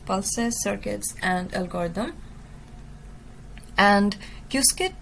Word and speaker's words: Qiskit: The second component is Qiskit, and it is quite a controversial topic of how we pronounce it, but I stick to what the Qiskit community members calls it themselves, and they say it Qiskit.